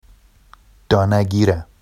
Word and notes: dɑnaɟira: Vafsi

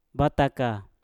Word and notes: bataka: mpur

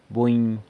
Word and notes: buny: San Lucas Quiaviní

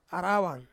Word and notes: arawan: Ansus

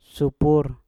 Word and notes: supur: mpur

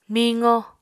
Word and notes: miqo: Apatani

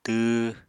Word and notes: tww: Apatani

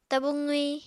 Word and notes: tabu qwi: Apatani

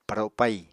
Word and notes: paro pai: Apatani

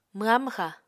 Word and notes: mwha-mwxa: Apatani

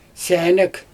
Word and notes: sʲænæk: Xyzyl

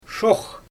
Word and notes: ʃoox: Xyzyl

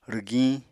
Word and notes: rwgiñ: Apatani

